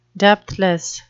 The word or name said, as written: depthless